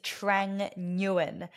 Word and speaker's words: Trang Nguyen: So my full name is Trang Nguyen. That's how I pronounce it, although it's a Vietnamese surname. So there's no right or wrong way to translate it into, you know, the English pronunciation.